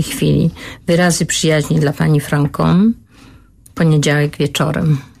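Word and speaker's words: Franchomme: Pisze oczywiście do Francom, bo we Francji wymawia się Francom, nie Franchom.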